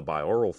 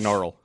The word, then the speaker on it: binaural: Binaural. – I was going to let it go. – No. – I keep saying it wrong, because that N makes no sense.